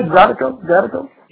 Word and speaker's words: Zydeco: I pronounced that right, Zydeco. – Yeah, Zydeco, that's right. Zydeco is a French term for a Creole term. Real Creole, not French. So it's a Creole term for the French word Zydeco, which means green beans, or beans, les Zydeco.